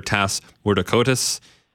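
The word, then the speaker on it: Tass Mourdoukoutas: So since we've last had you on, you've brought in an Australian defender, Tas Mourdakotis. I may have mispronounced his name. – You got it.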